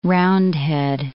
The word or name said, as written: Roundhead